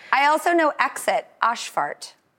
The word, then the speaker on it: Ausfahrt: Ausfahrt.